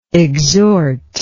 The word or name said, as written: exhortingly